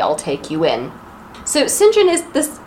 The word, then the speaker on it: St. John: That is spelled St. John. It's pronounced St. John. Why? I don't know.